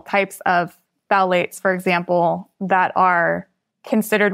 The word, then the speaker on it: phthalates: It's phthalates. – Phthalates. – But it's P-H-T-H-A-L-A-T-E-S.